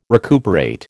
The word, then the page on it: recuperate: American English